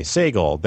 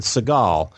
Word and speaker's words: Sagal: Luke actually pronounced Peter Sagal's name correctly last Thursday. Sagal. Then Sarah corrected him with Seagal.